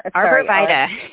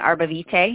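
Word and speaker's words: arborvitae: Arborvita. – Arborvita. Okay. Yeah. Arbor, like an arbor tree. – Okay. I got it. Canada. – Some people pronounce it Arbovite. If you're Canadian or French, you'd say Arbovite.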